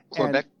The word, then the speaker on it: Quebec: Was born and raised in Montreal, Canada, or Montreal, Quebec, or Quebec, I guess is the correct pronunciation. Quebec? – Uh-huh. – Good. – Yep, Quebec.